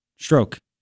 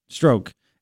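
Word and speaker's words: Strzok: Yes. Stroke. That's how you pronounce it. Stroke.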